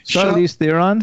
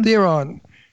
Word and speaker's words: Charlize Theron: Charlize Theron. – Theron, she's from South Africa.